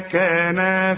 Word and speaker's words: كانا: اوعى تقول لي مما كان فيه، كان فيه لا، مما كانا فيه.